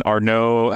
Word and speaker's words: Arnault: I just listened to like a, a four-hour podcast on uh, LVMH and Arnault. How do you say his last name? Arnault. Arnault.